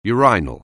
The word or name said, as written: urinal